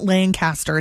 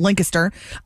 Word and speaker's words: Lancaster: It's not Lancaster, it's Lancaster. I've never been.